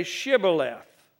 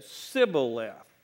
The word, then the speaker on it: Shibboleth: And when he said, "No," they said to him, "Then say Shibboleth." And he said, "Sibboleth," for he could not pronounce it right.